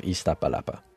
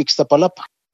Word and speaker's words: Iztapalapa: The vice president of the group that organizes the Via Crucis of Iztapalapa. – Yo le pronuncio con X siempre Iztapalapa. – Has been around long enough to pronounce the name the old-school way, with an X, Iztapalapa.